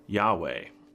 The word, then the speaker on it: Yahweh: There are no J's in the Hebrew alphabet. It's just a Y. It'd be Yahweh.